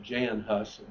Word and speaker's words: Hus: John Hus, Huss, I guess is the way you pronounce that, Jan Hus, John Hus, if you do it in English style, was from Czechoslovakia, or Bohemia it was called then.